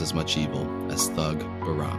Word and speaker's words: Behram: Behram. It's Behram. Did the research on the pronunciation of this one.